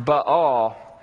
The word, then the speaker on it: Baal: His name is actually pronounced Baal. But I'm going to call him Baal just because Baal sounds a whole lot cooler than Baal.